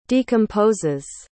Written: decomposes

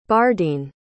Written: bardene

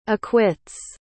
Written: acquits

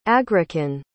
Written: agrican

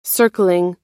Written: circling

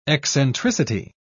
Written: eccentricity

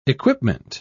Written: equipment

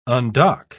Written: undock

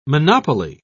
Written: monopoly

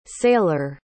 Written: saylor

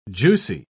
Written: juicy

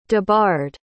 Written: debarred

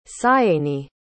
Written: sianey

